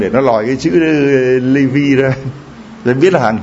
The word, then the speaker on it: Levi: Levi đó, hiệu hàng hiệu đó, đọc tiếng Anh gọi là Levi, còn tiếng mình Việt, tiếng Việt mình đọc là Levi, L-E-V-I.